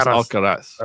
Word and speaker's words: Alcaraz: Carlos Alcaraz.